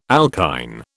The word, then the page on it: alkyne: British English